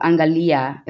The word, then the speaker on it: Angalia: And so Angalia, is that how you pronounce it? How do you pronounce your fashion brand? – It's called Angalia. It's in Swahili.